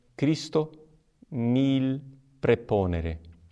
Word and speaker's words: Christo nihil praeponere: A pronúncia reconstituída, que seria aquela de Cícero, é Cristo nihil praeponere. Mas não é essa a nossa pronúncia. A pronúncia correta, romana, é Cristo nil preponere.